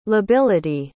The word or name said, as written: lability